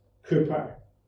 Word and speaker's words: Cowper: It's written Cowper, but pronounced Cowper.